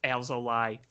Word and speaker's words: Alzolay: Speaking of very odd, I think today, we're recording this right before the game where Alzolay, and by the way, it's pronounced Alzolay, folks. That's what Len Casper said.